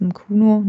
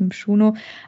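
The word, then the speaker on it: Mchunu: Also, sie haben ihn so ausgesprochen, deswegen glaube ich, er heißt Mkuno, nicht Mschuno.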